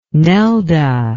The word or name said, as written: Nelda